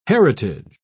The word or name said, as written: heritage